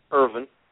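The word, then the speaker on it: Ervin: My name is Richard Irwin, and that's spelled Echo Romeo Victor Indian November.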